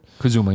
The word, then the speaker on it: Kazuma: Fight like hell through Tokyo and Osaka with protagonist Kazuma Kiryu. Is that how you say it? – Kazuma, yeah.